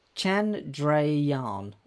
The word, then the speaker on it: Chandrayaan: I can't pronounce this very well. Chandrayaan. Tranjaran? Chandrayaan, apparently the ship's called.